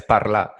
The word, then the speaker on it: parlar: So it's spelled parlar, but you drop the R. So it's just like French. You drop the R with all the verbs.